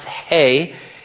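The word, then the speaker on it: hey (ה): It's pronounced hey.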